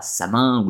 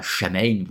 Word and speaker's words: Samhain: c'est la Samin ou la Chamaine, vous le prononcez comme vous voulez, c'est la seule véritable fête authentique, Halloween n'est qu'un plagiat commercial de tout ceci, et qui n'a donc aucun intérêt.